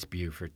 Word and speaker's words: Beaufort: It's Beaufort. – Okay. Beaufort, South Carolina, Beaufort, North Carolina.